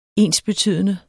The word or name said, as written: ensbetydende